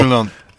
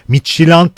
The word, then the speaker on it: Midtjylland: Midyoland'da da Midyoland hatta D'si yok. Midyoland. Evet, öyle söyleniyor. Bir internet sitesinde de öyle dendi. Ama Ç ile söylendiği de söyleniyor. Midtjylland diye söylendiği. J ile, ben biliyorsun Türkçe okunduğu gibidir. Midtjylland diye telaffuz edenler de var.